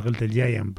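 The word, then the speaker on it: Gentle Giant: Yo juraría que desde Frank Zappa posiblemente a grupos de progresivo folkis tipo los Gentlegian, o los Gentlegiant, perdón, mal pronunciado, o cosas por el estilo.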